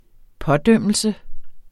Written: pådømmelse

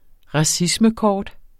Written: racismekort

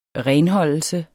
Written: renholdelse

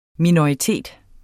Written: minoritet

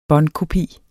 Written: båndkopi

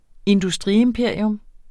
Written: industriimperium

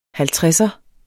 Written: HALVTREDSEREN

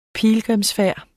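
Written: PILGRIMSFÆRDER

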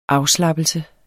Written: afslappelse

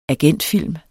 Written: agentfilm

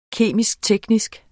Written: kemisk-teknisk

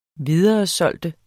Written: videresælge

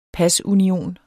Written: pasunion

